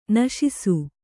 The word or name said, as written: ನಿಶಾಸ